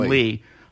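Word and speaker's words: Leigh: Phoebe Cates, Jennifer Jason Lewis. Jennifer, did I say that right? – Jason Lee.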